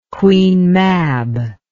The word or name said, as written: Queen Mab